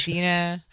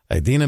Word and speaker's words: Idina: Adina. – Yeah, Adina's. – Abula or something. Like, he got it all wrong. – I should have been prepared with that. Idina Menzel, but he called her.